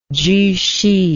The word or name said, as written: Zhu Xi